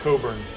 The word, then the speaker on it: Cockburn: Who's Bruce Cockburn? Isn't he a musician? – It's Coburn. – Coburn. – It's pronounced Coburn.